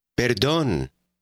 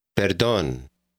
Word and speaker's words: perdón: He wants to begin a conversation, so he says, Perdón. – This, of course, is like the English word pardon, but the pronunciation is quite different.